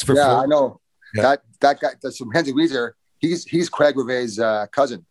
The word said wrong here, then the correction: Weegar: Is he Weezer? Is that how you say it? – Weegar. – That's how I say it.